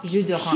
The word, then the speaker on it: jus d'orange: Jus l'orange? – Yes, she said it's d'orange, like of orange. And she said you don't pronounce the S. I'm just relaying. I don't speak French. – Well, jus d'orange.